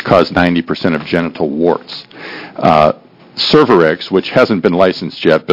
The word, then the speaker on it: Cervarix: Is it how it's pronounced? – Cervarix.